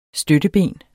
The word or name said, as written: støtteben